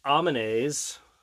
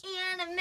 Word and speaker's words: anime: we started watching a couple of different Amenays. – That's not how you pronounce it. – Animals. We started watching a couple different animals. – Anime.